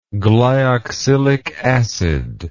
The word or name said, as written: glyoxylic acid